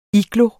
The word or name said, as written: igloo